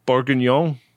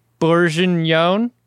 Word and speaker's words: Bourguignon: Bourguignon. – Bourguignon.